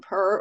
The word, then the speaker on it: Purr: Yeah, I think a lot of people pronounce it as, like, Juniper, or, like, yeah, I think a lot of people say Juniper, but it's really just June and Purr, or most people refer to me as June.